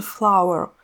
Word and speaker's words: flour: Так, допустим, буквально на днях я узнала, что цветок по-английски, понятно, это flower, а вот мука, которая пишется F-L-O-U-R, и которую я всю жизнь читала как flour, она, оказывается, произносится тоже flower. Мука и цветок — это омонимы. Пишутся они по-разному, а произносятся одинаково — flour.